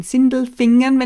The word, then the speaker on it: Sindelfingen: In Sindelfingen, wenn ich das jetzt richtig ausspreche.